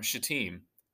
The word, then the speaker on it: Shittim: Then Joshua rose early in the morning, and they set out from Shittim.